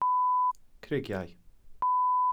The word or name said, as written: crugiau